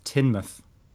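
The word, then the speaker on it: Teignmouth: It's pronounced Tinmouth. Tin, oh, for God's sakes. Tin Tinmouth. Tinmouth Devon. – Right. Yeah, they get rid of all the all the letters. They're all like redundant.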